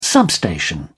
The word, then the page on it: substation: British English